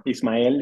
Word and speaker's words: Ismael: My name is Ismael in Spanish, but everybody, you can call me Ishmael. If you read or watched the movie Moby Dick, they say it like that.